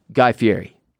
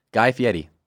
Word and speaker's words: Fieri: Imagine it's spelled F-I-E-D-I. Fieri. – Oh, like a D. I was thinking T. – It's called an alveolar trill, and it's kind of a fancy name for rolling your R's.